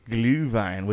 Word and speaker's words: Glühwein: Glühwein, which we very much enjoyed.